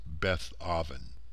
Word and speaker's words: Beth Aven: Let's now check out the term Beth Haven, or Beth Aven, which should be pronounced Beth Aven.